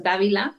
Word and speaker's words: Dávila: Mi nombre se pronuncia Genia, pero se escribe Genia con J y mi apellido es Dávila con D y con V.